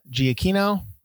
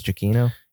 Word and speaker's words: Giacchino: Music by Michael Giacchino. I say that right? That's right. – I always mispronounce. I think it's Giacchino.